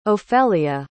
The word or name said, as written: ofelia